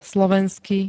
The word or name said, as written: slovensky